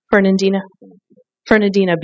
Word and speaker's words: Fernandina: Okay, so the last beach that Paul was on is Fernandino. Am I pronouncing that right? Fernandina Beach in Florida.